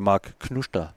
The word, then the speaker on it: Mark Knuster: Uh, vi skal jo lige nævne, at der, der var hjemmedebut til, til Mark Knuster. – Ja, øhm, hollandsk, flot udtalt. Som jo, ja, tak. Det har vi jo fået at vide, at det er sådan, det udtales.